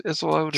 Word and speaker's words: Isolde: Isolde. Uh, how's it pronounced? Isolde.